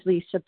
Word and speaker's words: Lesa: My name is Lisa B., and I'm a grateful recovered compulsive overeater, and I do spell it L-E-S-A.